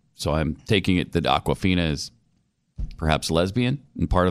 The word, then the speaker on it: Awkwafina: Isn't that a water bottle? – Yeah, that's a popular brand of liquid beverage. – I thought so. Mm-hmm. I love Awkwafina. This is spelled a little different than the water bottle. But Awkwafina.